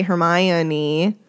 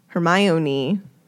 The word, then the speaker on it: Hermione: So Joe put this scene into the books to teach us all how to say Hermione. Hermione.